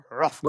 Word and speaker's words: Hrothgar: Well, I went to pronounce the H at the beginning of his name, but I realised we're just going to treat that like silent, aren't we? It's Rothgar.